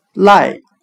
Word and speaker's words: lie: 我们用谐音法来记这个单词 lie，它的发音很像汉语的赖，抵赖的赖，赖账的赖。